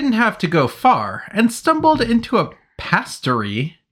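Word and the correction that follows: pâtisserie: Patisserie? How do you say it? – Patisserie. – Patisserie.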